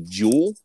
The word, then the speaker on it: Juhl: Writing credits for the movie go to Charles Dickens for the original novel, and I'm going to assume his name is pronounced Jerry Jewell, J-U-H-L.